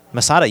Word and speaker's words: Masada: Starts with an M and rhymes with Asada. Masada, you got it.